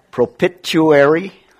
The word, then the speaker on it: propitiatory: Propitiatory, is that how you pronounce it? All right, good.